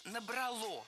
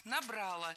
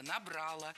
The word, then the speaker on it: набрало: Ударение поставлено неверно. Правильный вариант – «набрало». Дело в том, что большинство, можно даже сказать, все проблемные глаголы в прошедшем времени в среднем роде имеют безударное окончание. Прорвало, назвало, набрало.